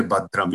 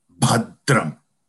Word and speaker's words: bhadram: The bha should be, you know, what they call it as mahaprana, which is deep sound. And this da should not be pronounced as da. It should be pronounced as dram, bhadram.